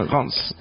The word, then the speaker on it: Reims: Encore un exemple à Reims, tu vois, j'y arrive pas, tu vois, voilà. Ouais, on prononce pas le N. – 30 ans de francophonie, j'arrive pas à prononcer Reims.